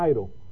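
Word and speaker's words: airo (αἴρω): Este verbo, es más, se pronuncia airo.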